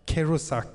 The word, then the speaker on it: Kerouac: Och sen så är det ju som den amerikanske författaren Jack Kerouac, uttalas det så? Ungefär. Tack.